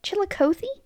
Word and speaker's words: Chillicothe: I looked up how to say this, and I totally forget. I think it's Chillicothe? – I mean, that sounds right. – The Chillicothe Correctional Facility, which is a state prison for women in Livingston County, Missouri.